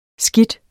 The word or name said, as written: skidt